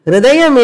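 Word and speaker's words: hṛdayam: See, hrudayam iti ha iti ekam aksharam. Hrudayam la hru, not ha. Hru is the first letter. First letter is art hru.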